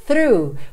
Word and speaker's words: through: E a minha próxima palavra é o through. Começa f-f-f também em linguinha lá nos dentes da frente.